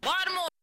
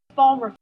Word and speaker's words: Baltimore: One of the most notable is the pronunciation of Baltimore or Balmer. Do you hear the difference? – Baltimore. – Balmer. – Listen again. – Baltimore. – Balmer. – Okay, I think you've heard the difference in the pronunciation of these two words, or what linguists call the phonological variation.